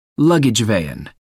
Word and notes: luggage van: American English

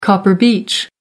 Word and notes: copper beech: American English

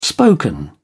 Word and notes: spoken: British English

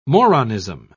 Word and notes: moronism: British English

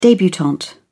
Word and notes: debutante: British English

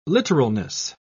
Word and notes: literalness: British English

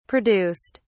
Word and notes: produced: British English

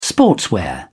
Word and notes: sportswear: British English

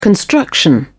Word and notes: construction: British English

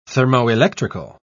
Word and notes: thermoelectrical: British English

